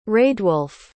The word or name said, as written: raedwolf